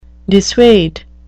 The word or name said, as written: dissuade